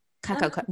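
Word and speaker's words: Kakao: is it Kaku? Have I pronounced that right? – Kakako. – Wow, that was shockingly embarrassingly bad. Kakako.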